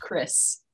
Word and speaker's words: Krzys: But it's a lot easier to say than it looks. It's pronounced Chris.